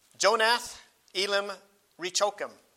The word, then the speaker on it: Jonath Elim Rechokim: To the chief musician upon Jonath Elim Rechokim. Aren't you impressed? I had to get out my other Bible so I could check the self-pronunciation text on that one.